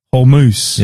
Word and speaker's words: Hormuz: Well, I was going to say, on the Strait of Hormuz. – Yes. – They've got. – That's the right pronunciation. – That's correct. Right. That's how you say it.